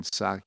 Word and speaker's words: Psaki: I think she pronounced it without the P, it's Jen Psaki, I believe, but anyhow, Psaki, repeatedly denied it's being a crisis, but also where FEMA was called in to assist in the situation.